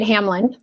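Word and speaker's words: Hamline: is it Hamlin or am I pronouncing it right? – That's right, Hamlin.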